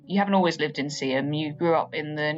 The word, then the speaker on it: Seaham: And you live in, is it Seaham? Am I pronouncing that right? – Yes. – Seaham.